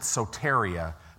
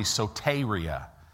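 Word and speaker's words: soteria (σωτηρία): It's the Greek word soteria. We pronounce it soteria, but it's really soteria.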